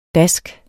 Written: dask

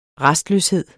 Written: rastløshed

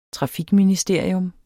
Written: trafikministerium

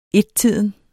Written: ettiden

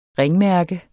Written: ringmærke

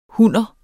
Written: hunner

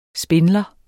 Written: spindler

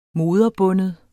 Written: moderbunden

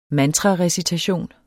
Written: mantrarecitation

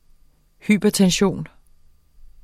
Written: hypertension

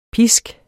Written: pisk

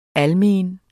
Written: almen

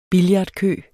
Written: billardkø

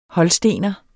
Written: holstener

